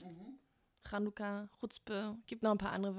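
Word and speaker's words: Chuzpe: Chutzpah? – Ich finde ich, ich find ja, find ja sowieso schon die Aussprache von Chutzpe relativ schwierig oder ungewohnt, weil man dieses Ch im, im Rachen ja.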